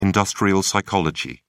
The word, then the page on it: industrial psychology: British English